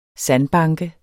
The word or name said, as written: sandbanke